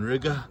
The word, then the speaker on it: Riga: So I'm out here, guys, in Riga, which rhymes with nigga.